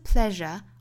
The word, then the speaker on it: pleasure: 后面的 the trace of pleasure or regret, trace 跟后面的 of 做连读， trace of 复原连读， the trace of pleasure or regret. 这句话里的 pleasure，后面一句里的 treasure，这两个词里的这个 s 字母啊，对应的发音呢应该是浊辅音的 r 的发音，大家不要发成了 treasure pleasure 之类的。